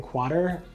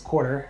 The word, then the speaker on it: Quattr: It's funny, because before you came in, I did a little intro and I pronounced the name, I think Quarter, but it could be pronounced Quarter, and you had the whole rhyme and reason around that.